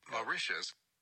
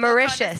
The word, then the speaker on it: Mauritius: Mauritius.